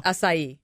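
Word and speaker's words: açaí: Acai bowl. – It's not how you say it. – No? – Acai. – Acai. – Yeah. – Really? – Yes. – Wait a minute. Are those the same thing? – Yes. Acai. – Terrible. – Bowls. Yeah, I don't know what you're saying. They're good. My daughter lives on them from the local Sobo place. It's spelled A-C-A-I. That's why.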